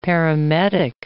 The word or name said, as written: paramedic